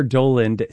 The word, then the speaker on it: Dowland: People have used the title of this piece to determine how you should pronounce his name. It's Semper Dowland, Semper Dolens, played here on the lute by Nigel North.